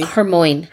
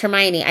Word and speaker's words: Hermione: Hermoine. That's what I did. – Book. – Me too. And when someone said, no, it's Hermione, I said, no, you're Hermione? – Wrong.